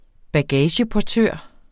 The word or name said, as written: bagageportør